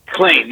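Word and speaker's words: Klain: It's pronounced Klein, not Klein, right? – Klein, yes.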